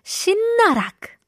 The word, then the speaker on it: 씻나락: Our first word of the day is 신나락. 오늘의 단어는 발음하기가 약간 어려운데요. 신나락이라는 단어 익숙하신가요? Today's word is a little difficult to pronounce.